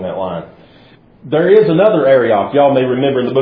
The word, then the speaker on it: Arioch: Now, the next person, Arioch, if I can pronounce his name in Hebrew like the experts can, it's like Arioch. It's something like that. It's like you're clearing your throat. Arioch, king of Ellasar.